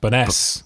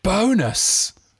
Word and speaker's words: Bowness: The definitive answer to the question, how do you say Bowness Baroness is in? and the real answer is we're all wrong. Oh, Baroness, B- bonus. – Bonus bonus, is what the locals say.